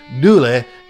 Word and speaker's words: Dula: The song is based on the real life story of a young Civil War veteran named Tom Dooley spelled D U L A, but pronounced Dule in the vernacular of Appalachian English.